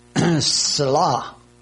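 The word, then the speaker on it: tsela: The Hebrew word is selah. That is translated rib. It's spelled T-S-E-L-A. T-S-E-L-A.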